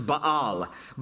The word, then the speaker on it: Baal: So God, through Zephaniah, first addresses the problem of idolatry in the land, and he points out the worship of Baal there in verse 4, more properly pronounced Baal.